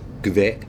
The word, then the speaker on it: Kveik: K-V-E-I-K. And he said, well, there are two ways to pronounce it. It's either Kvik or Kvik.